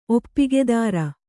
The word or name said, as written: ಒಪ್ಪಿಗೆದಾರ